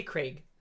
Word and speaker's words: Craig: How do you say that name? I say Craig. – Craig. – Yeah, Craig. – Like Greg, but with a K.